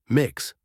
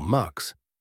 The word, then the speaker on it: Mx: And meet the newest member of the family, Asha MX, pronounced Mix or Mux, the title that breaks all the rules and welcomes everyone.